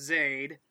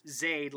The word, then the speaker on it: Zayd: All right, so it's pronounced Zayd. – Try it again. – Like Aid. – Yep. – Zayd, like Aid, but add a Z, just like it's Annie with an L.